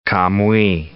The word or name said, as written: Camuy